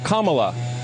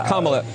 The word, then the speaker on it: Kamala: Her name is pronounced comma, like the punctuation mark, look, Kamala.